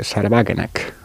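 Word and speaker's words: Sahra Wagenknecht: Porque hay, para mí, el experimento de partido más interesante que se está dando en la Unión Europea, por cierto, también putinista, es el de Sarwagenek, o como se diga en alemán, ¿no? – Está bien pronunciado, Sí.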